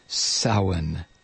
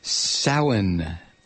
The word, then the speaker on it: Samhain: This is our Samhain program. Samhain. It's written as though it were pronounced Sam Hain.